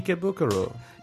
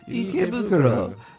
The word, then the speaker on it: Ikebukuro: And you get the correct pronunciation. Ikebukuro. – Ikebukuro.